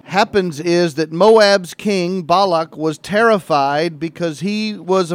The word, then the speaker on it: Balak: Balak is the way we pronounce it in Hebrew, B-A-L-A-K.